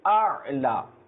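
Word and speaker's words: আ'লা (الأعلى): আচ্ছা এরপর দেখেন সুবহানা রব্বিয়াল আ'লা, দেখেন অনেকে আমরা বলে থাকি আলা, বা দুটাই হামজা উচ্চারণ করি, প্রথমটা হচ্ছে হামজা এবং দ্বিতীয়টা হচ্ছে আইন, প্রথমটা হচ্ছে কি হামজা এবং দ্বিতীয়টা হচ্ছে আইন, তো সেই জন্য আমাদের উচ্চারণ করতে হবে যে সুবহানা রব্বিয়াল আ'লা, সুবহানা রব্বিয়াল আ'লা, আর হামজা আইন জবর আর, হয়তো আশা করি আপনারা বুঝতে পেরেছেন।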